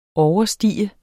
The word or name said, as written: overstige